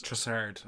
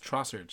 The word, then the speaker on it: Trossard: It's Trossard, Seamus. You say it like a, you have to say it like a bogger. Trossard. Trossard. – Yeah, you're saying it like a guy from Limerick, whereas I'm saying it like a guy from Belgium.